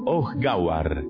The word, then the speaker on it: Ohgawar: se llama Ohgawar, y está al mando del comandante Washar. La H se pronuncia como J.